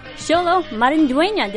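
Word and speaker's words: Xolo Maridueña: And we are so excited to have special guest Xolo Marindueña.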